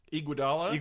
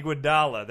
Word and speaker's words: Iguodala: Andre Iguodala. – Iguodala, there we go.